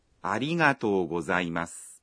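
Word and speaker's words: Arigato gozaimasu (ありがとうございます): Khi nói arigato thì các bạn kéo dài nguyên âm o trong to ra một nhịp.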